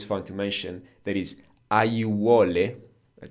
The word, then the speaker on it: aiuole: You don't need to know this word, but it's just an example that when we have four vowels, a, i, u, o, close together, we just pronounce each and every one of them. Aiuole. – Aiuole.